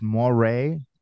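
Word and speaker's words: moiré: It's it's moire. – It's yeah, moire, M-O-I-R-E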